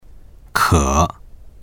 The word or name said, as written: ke3